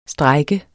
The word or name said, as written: strejke